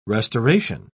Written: restoration

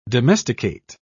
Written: domesticate

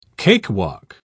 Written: cakewalk

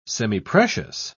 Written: semiprecious

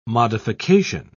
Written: modification

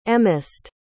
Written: emest

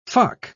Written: fuck

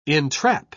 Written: entrap

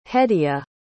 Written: hedia

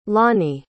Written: lonie

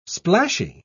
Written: splashy

